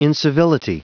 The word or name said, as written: incivility